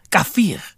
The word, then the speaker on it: kafir: Nobody should be giving a fuck about the word kafir or kafir. Right, because that's the proper pronunciation, isn't it? Kafir, which means non-believer.